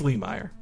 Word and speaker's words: Lemire: It's Jeff Lemire, or no, Jeff Lemire. I was corrected on pronouncing it because we have one very good follower who like lives in his hometown in Canada, and he's like, it's Lemire. But so Jeff Lemire is doing a very good run on Moon Knight.